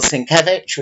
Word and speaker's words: Sienkiewicz: And the inker Bill Sienkiewicz, which is how you pronounce it.